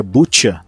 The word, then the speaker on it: Bucha: O massacre em Bucha que se pronuncia Butcha, né? Vamos tentar falar certinho.